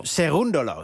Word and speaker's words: Cerúndolo: Novak Djokovic moest eerder dus op deze avond het onderste uit de kan halen om de kwartfinales te bereiken tegen de Argentijn Cerúndolo. Zeg ik het zo goed? – Cerúndolo. – Het gaat altijd om de klemtoon.